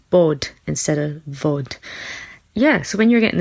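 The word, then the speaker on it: Bhòid: which is Vod, and that's spelled B H O I D.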